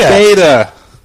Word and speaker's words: data: You said Dada. – Hi, Dada. One Dada. 10 gigabytes of Dada. – Am I not saying it right? – Data. – Data. – Data plan, Dada plan. Who the fuck says Dada? – I thought it was a normal word.